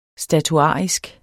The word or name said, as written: statuarisk